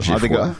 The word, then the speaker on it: Geoffroy: Je, Geoffroy, comment, comment on prononce ça? J'ai froid. j'ai froid.